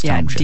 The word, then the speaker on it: Tomchick: And it's spelled like it sounds, Tomchick. – Yeah, Tomchick, T-O-M-C-H-I-C-K, @drizzle, D-R-I-Z-Z-L-E.